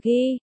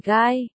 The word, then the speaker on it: Guy: Đọc là Ghi, chứ không phải Guy, Robin nói, gì kia, tên của ông ấy, anh phải đọc theo kiểu tiếng Pháp là Ghi.